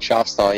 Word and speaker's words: Schoffstall: Schoffstall, yeah.